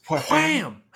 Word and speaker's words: Wham!: Wham!